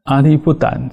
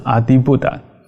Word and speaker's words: Adibuddha: 这阿利布达其实它正确发音应该比较像是阿迪布达，就 ADIBUDDHA。